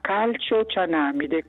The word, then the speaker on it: calciocianamide: Poi vorrei sapere ancora la parola che io ho scritto, calcio manida, come si pronuncia? – Allora adesso le rispondiamo a tutte e due le cose. Prima andiamo sulla parola. – Calciocianamide. – Calciocianamide.